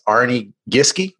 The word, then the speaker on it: Arne Giske: Today, we have an amazing guest, Arne Giske.